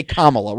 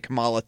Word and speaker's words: Kamala: We're all gonna try to say Kamala. We're gonna try to do it best. When I was talking to Joe on Sunday, I said it Kamala too at one point because there was a wrestler called Kamala.